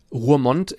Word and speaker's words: Roermond: Also ich habe zum Beispiel auch gelernt, dass Roermond in Holland gleichzeitig ist.